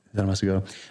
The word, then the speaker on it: Tadamasa Goto: And before I get to, I'm not going to butcher the name, Tadamasa Goto. Is that how you? – Yeah. – Tadamasa Goto.